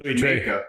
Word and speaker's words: Louis XIII: and the guy was doing a review of Louis XIII. – You mean Louis Trey? – Huh? – Louis Trey, I know. – You got to say it right.